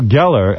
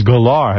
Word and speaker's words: Gellar: And I did an interview with her where I thought her name was Sarah Michelle Gellar, and she says the actual pronunciation is Gellar.